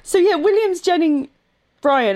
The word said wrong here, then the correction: William Jennings Bryan: I think I can't say his name. What is wrong with me today? William Jennings Bryan.